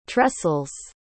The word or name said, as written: tressels